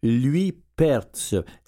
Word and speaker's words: Luypaerts: Et puis j'ai un autre nom bizarre à vous prononcer, Claudine Luypertz, L-U-Y-P-A-E-R-T-S.